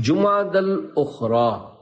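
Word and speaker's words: جمادى الآخرة: لغت کے اعتبار سے ان دونوں مہینوں کے درست نام اور صحیح تلفظ یہ ہے، جمادہ الاولی، جمادہ الاخری اور جمادہ الاخرہ.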